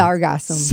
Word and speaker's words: Sargassum: Sargassum.